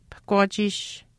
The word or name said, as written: pahkwaachiish